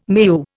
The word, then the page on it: meu: Indian